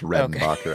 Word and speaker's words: Redenbacher: One of my dad's coworkers is like the great grandson of Orville Reidenbacher, like the, Redenba- the good popcorn. Is it pronounced Redenbacher? – No, it's Redenbacher. Okay. I always add an I.